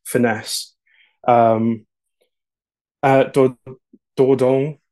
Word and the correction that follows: Dordogne: or I, I, hope that's how you say it. – Dordogne, sounds French. Dordogne.